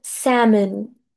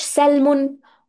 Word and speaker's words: salmon: بالله عليكم بلاش سلمون وسلمون والحاجات دي، هو اسمه سامن. حرف ال لا ينطق تماما. سامن، سامن بيتكتب S A L M O N. سامن، سامن، سامن، مش سلمون خالص.